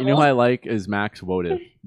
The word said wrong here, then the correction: Wax Motif: I totally said that wrong. Wax Motive. – Wax Motif. – Motif. See, I totally. At first I said Max.